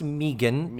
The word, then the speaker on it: Megan: So the actress is Megan Follows, and it is pronounced Megan. That was put explicitly in the IMDb page about her.